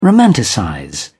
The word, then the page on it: romanticize: British English